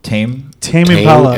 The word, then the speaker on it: Tame Impala: Tame Impala? – Tame Impala.